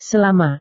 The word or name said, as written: selama